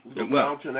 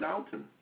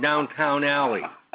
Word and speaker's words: Downton Abbey: You're not a fan of Downton? – No. – Why is that? Everybody loves Downton. – Because I don't know how to say it. I'm always calling it... I'm always calling it Downtown Alley.